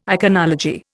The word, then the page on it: iconology: Indian